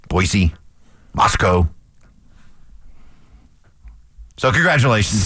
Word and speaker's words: Moscow: Remember I said it right. Moscow. Moscow. – Yeah, Moscow.